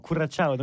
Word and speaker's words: Curaçao: Curaçao, Curaçao.